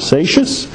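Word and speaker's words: Seixas: It's S-E-I-X-A-S, but it's pronounced as Satius, 1745 to 1816.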